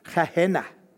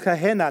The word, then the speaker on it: Gehenna: Gehenna. So it's an actual physical place, not just the place that the Bible mentioned. There's a physical place called Gehenna, and I'm going to ask you to put a picture up for me and direct your attention to this picture.